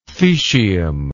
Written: thecium

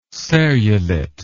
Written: serrulate

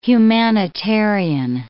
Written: humanitarian

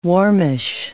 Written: warmish

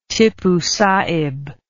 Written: Tipu Sahib

